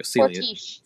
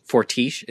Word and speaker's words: Fortiche: Fortiche. – Fortiche, is it that? – Mm-hmm. – Okay, it's Fortiche.